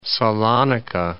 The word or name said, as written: Salonika, Gulf of